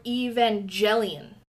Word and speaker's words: Evangelion: How do you pronounce... Is it Evangelion or Evangelion? – Well, in Japanese, they say Evangelion.